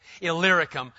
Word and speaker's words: Illyricum: So that from Jerusalem and all the way around to, if you want to know how to pronounce that word, just look for the little word Lyric inside Illyricum. It makes it a lot easier. I failed a lot of times trying to pronounce that word until you see that little word Lyric in there, then you're set.